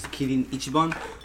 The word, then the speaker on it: Kirin Ichiban: In Japan it's pretty common, but this is Kirin Ichiban.